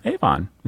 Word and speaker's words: Avon: Avon. Avon is how we pronounce it.